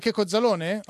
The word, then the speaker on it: Checco Zalone: Allora sono rimasti Lino Banfi, la coppia Boldi De Sica, Roberto Benigni, Claudio Bisio, Aldo Giovanni e Giacomo e Checco Zalone? – Sì, detto bene? – Hai detto benissimo.